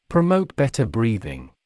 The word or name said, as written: promote better breathing